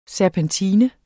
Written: serpentine

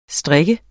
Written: strik

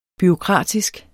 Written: bureaukratisk

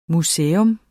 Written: museers

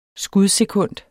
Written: skudsekund

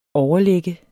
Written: overlagt

